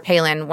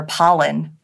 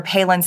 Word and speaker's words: Palin: from all the news outlets were calling her Pollen. They didn't know she was even on the shortlist. They didn't know how to pronounce her last name.